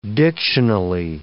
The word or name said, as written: dictionally